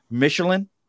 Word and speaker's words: Michelen: Michelen.